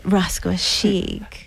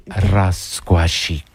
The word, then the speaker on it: Rascuachic: You have to say it, Rascuachic. – You have to roll the R's. Rascuachic.